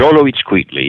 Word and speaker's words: Xoloitzcuintli: The Xoloitzcuintli.